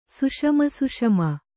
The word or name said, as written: ಸುಷಮಸುಷಮ